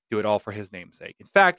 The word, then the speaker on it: NMSK: So it's going to be N-M-S-K, but it's going to be pronounced Namesake.